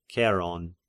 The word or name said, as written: Ceron